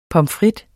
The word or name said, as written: frites